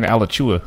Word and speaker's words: Alachua: Alachua. Alachua. – You're right. Oh, so I'm saying it right. – I'm supposed to be right. – See? See? Alachua. Alachua. It's Alachua. I was right.